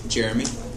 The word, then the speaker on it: Jeremy: Jeremy. – Okay, Jeremy, how do you spell it? – J-E-R-E-M-Y.